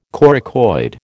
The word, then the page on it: coracoid: American English